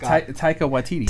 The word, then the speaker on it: Taika Waititi: Taki Wakiki. – God bless you. – He's all Hakuna Matata. Hakuna Matata's in Sorry, let me say his name right. It is. – Taika Waititi.